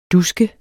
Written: dusket

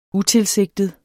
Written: utilsigtet

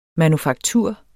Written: manufaktur

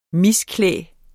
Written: misklæde

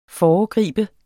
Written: FOREGRIBE

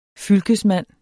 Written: fylkesmand

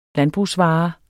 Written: landbrugsvare

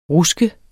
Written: RUSKET

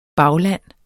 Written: bagland

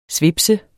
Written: svipser

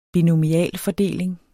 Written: binomialfordeling

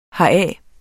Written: heraf

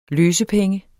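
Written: LØSEPENGENE

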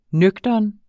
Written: nøgtern

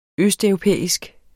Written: østeuropæisk

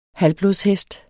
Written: halvblodshest

